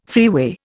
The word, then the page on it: freeway: Indian